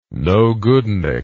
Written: nogoodnik

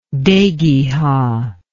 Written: dhegiha

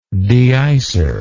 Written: deicer

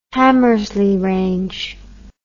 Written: Hamersley Range

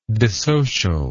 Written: dissocialize

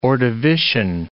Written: Ordovician

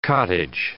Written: cottage

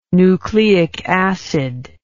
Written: nucleic acid

